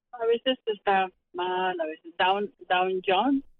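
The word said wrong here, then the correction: Dow Jones: ¿Cómo se dice? ¿El Dow Jones? – Ajá.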